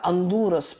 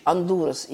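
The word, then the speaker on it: Honduras: Теперь Гондурас, или, как правильно произносить название этого государства, Гондурас, да, Андурас по-испански, э, вот так звучит Андурас именно, это название этой страны.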